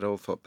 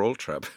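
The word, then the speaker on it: Althorp: There's across the hill from us at Althorpe, there's, or Altrab, depending on the pronunciation, there's Holdenby or Holmby Palace, or was there.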